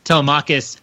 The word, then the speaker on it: Telemachus: So the opening books of the Odyssey are all about Telemachus. I added a syllable there. Telemachus, Odysseus's son, travels around hearing all the stories of the other heroes of the Trojan War who came home.